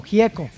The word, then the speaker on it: Gieco: Y tenemos a León Gieco, o Gieco, como lo pronuncian los argentinos.